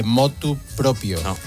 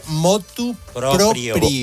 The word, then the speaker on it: motu proprio: Por ejemplo, dice, el asesino confesó su crimen a la autoridad motu proprio. – Proprio. – No se dice ni el de ni el propio.